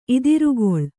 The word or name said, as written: ಇದಿರ್ಗೊಳ್